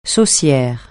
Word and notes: saucière: francais

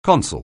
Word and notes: consul: anglais

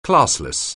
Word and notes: classless: anglais

